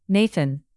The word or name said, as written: nathan